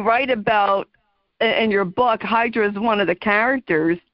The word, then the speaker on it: Hydra: It makes me want to go to Hydra, which in Greece they pronounce it Hydra, which is interesting.